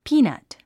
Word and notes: peanut: píːnʌ`t; ピィナッツ

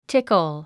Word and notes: tickle: tíkl; チッコォ

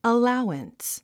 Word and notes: allowance: əláuəns; アラァワンス

